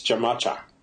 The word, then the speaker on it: Jamacha: Hamashah is not spelled like ham. – No, it's with a J. – Yeah, it's Jamacha. – Jamacha.